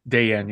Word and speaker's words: Dellien: It currently is against Hugo Dayen. Is that how you say it? – Dayen, yeah.